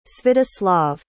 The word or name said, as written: svyatoslav